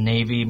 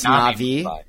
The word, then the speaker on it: Navi: And it is Get Muslim Black Magic Specialists in Navy Mumbai. – It's pronounced Navi. It's pronounced Navi.